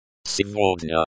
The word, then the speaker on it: Сегодня: Сегодня. Сегодня. Means today. Note the stress in the word.